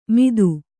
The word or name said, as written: ಮಿತ್ತು